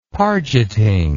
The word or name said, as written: pargeting